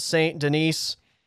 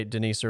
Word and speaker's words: Saint-Denis: All right, in the lightweight division, we head to the second fight on this main card, Benoit Saint-Denis. By the way, I usually say Saint-Denis, but I heard you say Denise earlier, so I'll roll with your pronunciation.